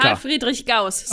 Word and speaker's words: Carl Friedrich Gauss: Carl Friedrich Gauss.